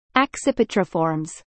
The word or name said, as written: Accipitriformes